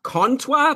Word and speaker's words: Comtois: Okay, Matthew in the chat room is telling us that Comtois, as we've been calling him, his name is pronounced Comtois, but there's no N. – No, but like Comtois, like if you say it with a French accent, it sort of lends itself to that.